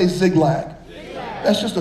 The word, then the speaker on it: Ziklag: Some translations spell it Z I K L A G. Some spell it Z I G L A G. But either way, Ziglag.